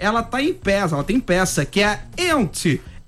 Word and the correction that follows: Yentl: Ent in Concert. – Yentl. – Eu vou fazer com pronúncia Yentl in Concert.